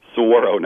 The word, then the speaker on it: Saguaro: Saguaro National Park, yes.